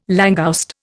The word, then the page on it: langouste: Indian